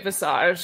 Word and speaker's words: visage: I'm pretty sure we're just going to keep calling it visage. – So yeah. we're just it rhymes with massage.